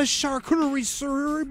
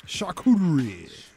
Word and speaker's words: charcuterie: could you pick up a charcuterie board from Tom Thumb? – It's not how you say it. – Charcuterie.